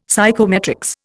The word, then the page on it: psychometrics: Indian